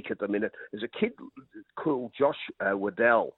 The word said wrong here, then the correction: Weddell: Weddell, in fact, it would be the correct pronunciation, a boy that was picked in that All-Australian side that really is physically ready to go.